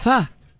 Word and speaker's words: pho: P-H-O is pronounced, I think it's pho. – It's pho. – It's pho. – Pho. – Is it pho? – Pho. Yeah. Yeah, pho? Don't add anything else after that, please.